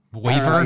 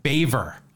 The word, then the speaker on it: Boever: Boyver? You said that. Beaver? – Baver. I'm sorry. I mispronounced that completely. There is no A in it. B-O-E-V-E-R, but the pronunciation is Baver.